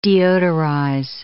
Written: deodorize

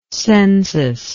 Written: censual

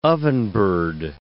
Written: ovenbird

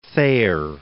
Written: Thayer

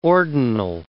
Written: ordinal

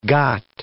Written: got

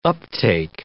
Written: uptake

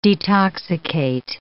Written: detoxicate

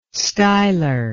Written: stylar

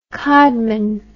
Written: codman

